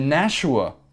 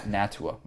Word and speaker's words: Nashua: So in the Nashua, not Natua, Marler. In the Nashua, I was pronouncing the T as an SH, to my defense. – It's like a ch.